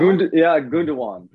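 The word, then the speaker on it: Gündoğan: So Gerdawan, am I pronouncing his name? – Gundawan. – Gundawan.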